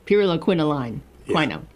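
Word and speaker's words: pyrroloquinoline quinone: They list one of their top supplements that they say right here, these doctors, and again based on the clinical trials, is pyriloquinone. Did I say it correctly? – Pyriloquinoline quinone.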